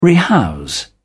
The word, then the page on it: rehouse: British English